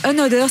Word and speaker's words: Another: Ça s'appelle Another, ça s'écrit un autre, mais ça se prononce bien Another.